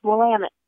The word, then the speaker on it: Willamette: And for the record, it's a really tricky word if you're not from here. But us locals, we pronounce it Willamette. – Willamette. And one of the ways, yes, and one of the ways that the tour companies have you remember that is they say it's Willamette, damn it.